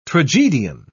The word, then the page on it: tragedian: British English